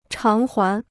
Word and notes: 偿还: cháng huán

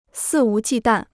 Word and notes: 肆无忌惮: sì wú jì dàn

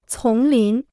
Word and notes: 丛林: cóng lín

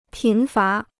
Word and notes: 贫乏: pín fá

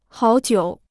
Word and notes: 好久: hǎo jiǔ

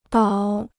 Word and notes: 岛: dǎo

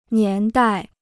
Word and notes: 年代: nián dài

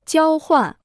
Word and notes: 交换: jiāo huàn

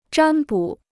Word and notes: 占卜: zhān bǔ

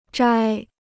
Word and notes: 摘: zhāi

